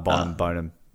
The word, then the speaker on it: Bonham: Like, so I always pronounced it Boneham. – Yeah, I think it's Bonham. I think I said it wrong. Bonham.